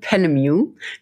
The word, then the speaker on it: Penemue: Also es wird ausgesprochen Penemu. Wer sich das nicht merken kann, ich sage immer wie Music.